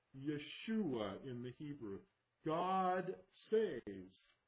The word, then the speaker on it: Yeshua: Jesus is simply the Greek version of Joshua, and Joshua is not pronounced Joshua. That's how we put it into the English. It's actually Yeshua.